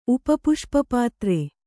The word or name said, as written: ಉಪಪುಷ್ಪಪಾತ್ರೆ